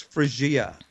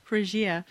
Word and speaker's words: Phrygia: Phrygia? – I think we worked out it's Phrygia, Phrygia, but we'd have to ask Cliffy. But I think it's Phrygia. – Phrygia.